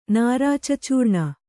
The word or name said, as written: ನಾರಾಚಚೂರ್ಣ